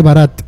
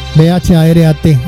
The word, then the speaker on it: Bharat: Ellos no, cuando ellos hablan allá, que la República de la India, dicen la India, dicen la República de Bharat. Bharat, así se llaman ellos allá. Es la forma como ellos pronuncian el nombre de su país allá en la India.